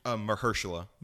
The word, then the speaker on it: Mahershala: Mahershala.